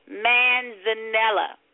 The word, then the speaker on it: manzanilla: I got a pimento stuffed Spanish manzanilla olives. I know I didn't put the emphasis on the right word with that manzanilla. Manzanilla.